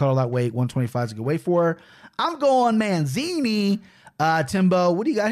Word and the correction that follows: Mazzani: Mazzani. – Yeah, Mazzani. It's like, excuse me. There's not an N before the Z.